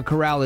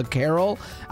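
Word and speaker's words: carrel: And no, I didn't pronounce corral wrong. It's not a corral. It's spelled C-A-R-R-E-L, a carol.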